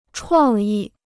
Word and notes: 创意: chuàng yì

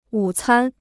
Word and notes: 午餐: wǔ cān

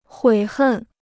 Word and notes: 悔恨: huǐ hèn

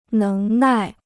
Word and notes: 能耐: néng nài

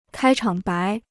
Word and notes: 开场白: kāi chǎng bái

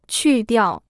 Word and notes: 去掉: qù diào

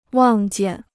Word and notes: 望见: wàng jiàn

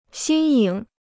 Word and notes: 新颖: xīn yǐng

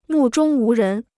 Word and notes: 目中无人: mù zhōng wú rén

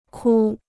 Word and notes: 哭: kū

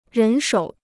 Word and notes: 人手: rén shǒu

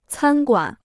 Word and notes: 餐馆: cān guǎn

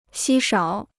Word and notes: 稀少: xī shǎo

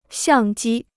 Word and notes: 相机: xiàng jī

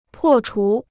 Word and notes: 破除: pò chú

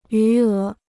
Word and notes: 余额: yú é

